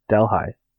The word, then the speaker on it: Delhi: In episode 164, Deli was mispronounced by Thomas. The proper way to say it is Dell as in Dell computer, high as in Delhi.